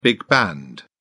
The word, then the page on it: big band: British English